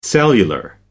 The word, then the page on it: cellular: American English